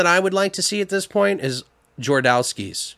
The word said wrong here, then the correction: Jodorowsky: Well, I always say his name wrong, but it's Alejandro Jodorowsky, who did Holy Mountain.